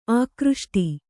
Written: ಆಕೃಷ್ಟ

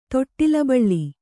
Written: ತೊಟ್ಟಿಲಬಳ್ಳಿ

